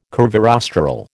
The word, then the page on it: curvirostral: American English